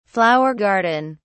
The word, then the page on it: flower garden: British English